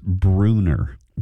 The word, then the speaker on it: Brunner: Oh, John the Sunbelt Mechanic says it's pronounced Brunner. – Brew. – Even though it's spelled B-R-U-N-N-E-R, it's pronounced Brunner. – Well, kind of like Brubaker, B-R-U. That makes sense. Brunner.